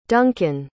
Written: Duncan

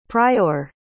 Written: priour

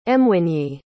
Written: mwinyi

